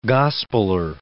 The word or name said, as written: gospeller